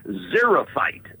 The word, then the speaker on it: xerophyte: The word of the day is xerophyte.